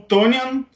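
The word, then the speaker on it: Tonyan: O, o Tonian, me corrija, eu não sei como pronunciar o nome dele direito, não. – É isso aí mesmo, Robert Tonian.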